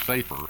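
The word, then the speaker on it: sefer (ספר): And if you consider Genesis 5:1, which says this is the book of the generations of Adam, the word book, or sefer, pronounced sefer actually in Hebrew, actually means a book or a scroll.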